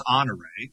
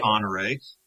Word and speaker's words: Honoré: It's Honoré, and it's pronounced Honoré. And there's no doubt that it's pronounced Honoré, and Bertha pronounced it Honoré, and her son Honoré Palmer pronounced it Honoré. And so what you find is that people that have lived in Sarasota for a long time call it Honor and insist that it's Honor. But when a street is named for a person, it really should be pronounced in the manner that the family pronounced the name.